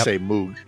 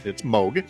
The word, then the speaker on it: Moog: This is the anniversary of the birth of Dr. Robert Moog. I said that right. I'm always tempted to say Moog, but it's Moog.